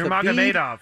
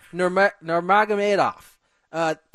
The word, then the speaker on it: Nurmagomedov: Nurmagomedov. – Nurmagomedov.